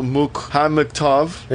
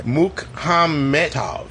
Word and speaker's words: Mukhametov: I guess the main character is called Victor, and the actor name was called Rinal Mukhametov. – You didn't pronounce the E there. Mukhametov, not Mukhamet.